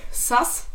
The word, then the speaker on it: sus: Nee, also, Auflösung, Sass. Wird es ausgesprochen.